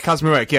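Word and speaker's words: Kaczmarek: how do you pronounce her name? Kazmarek, I think. – Kazmarek, yeah.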